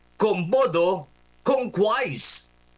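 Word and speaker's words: quomodocunquize: Ang spelling niya ay Q-U-O-M-O-D-O-C-U-N-Q-U-I-Z-O-Z sa iba at E. Q-U-O-M-O-D-O-C-U-N Q-U-I-Z-E. This is a Latin verb. Okay? Latin verb po ito na ang Latin pronunciation ay commodo conque. Ba, natuto si Johnny G ngayon. Alright? Ang Latin term po nito ay commodo, o Latin pronunciation ay commodo conque. Pero ang English pronunciation po nito ay commodo conquise, na ang ibig sabihin, to make money or profit by any means possible, often regardless of ethics.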